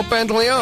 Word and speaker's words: Pantoliano: Pantoliano? – Joe Pantoliano.